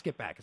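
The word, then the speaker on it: Skippack: Ann, how do you pronounce Skipack? Is that right? – You got it right.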